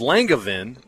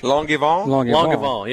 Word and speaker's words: Langevin: It's Langevin. So we all thought it was Langevin, but he then Jay gave the real pronunciation of it, the French. – Langevin.